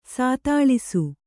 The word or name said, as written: ಸಾತಾಳಿಸು